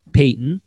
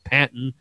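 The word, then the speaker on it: Paton: We are calling him General Peyton, because that's how it's pronounced, and because we want to see how many times we can try and say that and not say General Patton, which is what we desperately want to say.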